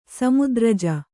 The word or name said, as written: ಸಮುದ್ರಜ